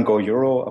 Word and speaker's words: GoEuro: Ja, also, also wir sagen Go Euro, aber du kannst es aussprechen, wie du möchtest.